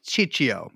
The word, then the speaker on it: Ciccio: How would I pronounce that? Ciccio. – Yeah.